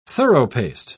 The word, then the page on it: thoroughpaced: British English